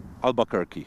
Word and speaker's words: Albuquerque: To jest Albuquerque, Albuquerque, jak się to wymawia? – Albuquerque. – Okej.